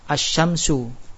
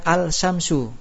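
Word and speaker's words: asy-syamsu (الشمس): Lamnya tidak dibaca seperti kita membaca lam pada syams, asy-syamsu, ya. Enggak baca al-syamsu. Benar enggak? Atau kalau kita baca al-syamsu, benar apa salah? Salah, tapi bacanya apa? Asy-syamsu.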